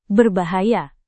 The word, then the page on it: berbahaya: indonesian